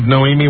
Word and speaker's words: Noemie: So listen, no, how do you even pronounce this name? N-O-E-M-I-E? – Noemi? – Noemi Leno.